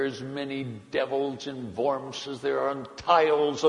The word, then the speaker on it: Worms: W-O-R-M-S, pronounced Worms.